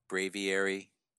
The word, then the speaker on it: Braviary: Which is Bravery. Bravery. Bravery. I think I said it right, right? – Braviary. – Braviary. That's what I said. Bravery. Mm-hmm. – You said it like Scooby-Doo would say it.